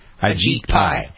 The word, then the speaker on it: Ajit Pai: Ajit Pai.